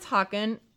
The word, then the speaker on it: Haakon: Sorry, I need to get my pronunciation right here. Crown Prince Haakon and Crown Princess Mette-Marit.